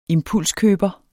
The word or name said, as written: impulskøber